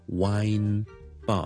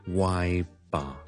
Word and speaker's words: wine: 今日咧同大家讲一讲一个 pronunciation 嘅问题，因为萧叔咧听到有人讲呢个字就读得麻麻地，就系咧小酒吧，佢哋叫做一个 wine bar，啊 wine bar。听到有人将佢读成就 why bar why bar，咁当然大家听到个问题喺边咧，就系冇咗个 n 音，OK？